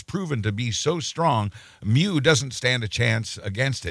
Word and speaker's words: Mu: Is it Mu? – It's the Greek pronunciation is Mu.